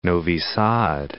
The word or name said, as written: Novi Sad